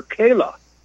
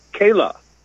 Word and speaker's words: Kalah: Hugh Auchincloss is going to be working with his daughter, Kayla. And that's spelled K-A-L-A-H, Kayla.